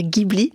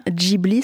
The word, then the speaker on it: Ghibli: Les studios de Ghibli, alors peut-être d'ailleurs, précisons que la prononciation en entend souvent dire Ghibli. Or, la prononciation exacte, c'est bien Ghibli.